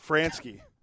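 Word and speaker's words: Franzke: Scott Fransky.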